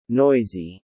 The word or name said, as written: noisy